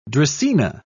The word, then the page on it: dracaena: British English